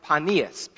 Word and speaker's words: Panias: It was originally known as Panias, P-A-N-E-A-S, Panias. And then it became known as Caesarea Philippi, and then eventually later it became known as Panias, P-A-N-I-A-S.